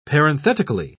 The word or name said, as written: parenthetically